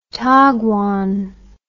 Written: taguan

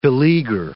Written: beleaguer

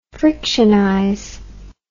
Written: frictionize

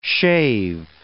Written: shave

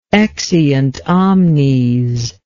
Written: exeunt omnes

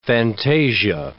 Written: fantasia

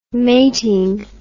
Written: mating

